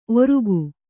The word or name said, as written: ಓರ್ಗೈ